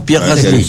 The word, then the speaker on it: Pierre Gasly: Pronuncie bien, ¿no? Pierre Gasly. – Sí, qué bien sexto.